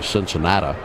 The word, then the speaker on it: Cincinnati: That's why you still hear some people say Cincinnata when they pronounce his place.